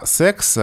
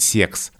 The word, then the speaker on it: секс: Но вот именно слово «секс» обычно произносится в норме с твердым «с», а носители языка старшего возраста, от них вот можно услышать «секс» с мягким «се», и вот здесь это слово нарушает эту закономерность.